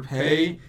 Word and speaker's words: Faye: So they don't have F, so it's Pei? Like Pai or Pei. – Pei. – It was, oh, they pronounce it weird, but we'll say Faye because that's how it's spelled.